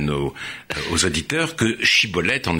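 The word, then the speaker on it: shibboleth: il lui disait Prononce donc Chibolette. Et il prononçait Cibolette, car il ne se réussissait pas à parler correctement.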